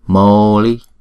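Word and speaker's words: mauli: Moli. Chữ A U đọc là Âu. Không. Âu. Âu. Hay chúng ta đọc hai nhịp.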